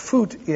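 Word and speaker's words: Voet: Fuchsius, his name was Foot, F-V-O-E-T, which is pronounced Foot in Dutch, and it means foot.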